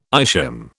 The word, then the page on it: ischium: British English